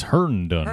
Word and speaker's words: Herndon: Moving on to another pick here in the fourth round, Chris Henderson to the New York Jets, tight end out of Miami. – It's Herndon. – Herndon, yep. Sorry.